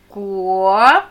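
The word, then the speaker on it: Guo (果): Guo. Spüre den Unterschied. Guo. Guo. Guo, Guo. Die Silbe besteht aus Stimmlosen wie ein Kelle K und Vokalen U, O. U ist sehr fließend. Betonung auf O.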